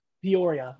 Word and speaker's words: Peoria: Peoria.